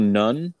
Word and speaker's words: Nunn: Bill Nunn, or Noon. I'm assuming it's Nunn? Noon? – He allows either pronunciation.